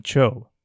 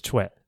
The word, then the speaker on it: Choe: In English, you'll see it romanized as C-H-O-E most of the time. And so many people will actually call him Cho. But the correct pronunciation is Choe. Sidebar, if you ever see the Korean name C-H-O-I or Choi, it's actually the same name pronounced Choe.